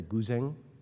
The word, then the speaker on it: guzheng: Bangladesh and the guz, how do you say this? Sarah, is it the guzheng from China? Guzheng, okay, thank you.